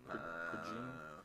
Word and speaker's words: Prejean: Is that how you pronounce her name? – Prejean.